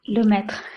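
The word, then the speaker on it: Lemaître: sacerdote de la época que se llamaba Lemaitre, creo que se pronuncia algo así en francés. Bueno, será Lemaitre. – Lemaitre.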